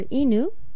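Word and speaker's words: inu (いぬ): And the pronunciation of dog is either inu, and also sometimes ken.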